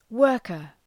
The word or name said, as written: worker